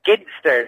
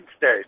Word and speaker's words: Ginsters: It is Ginsters. I'm Cornish and it's definitely Ginsters. – It's not arguable. – It's a hard G, definitely. It's a go, Ginsters.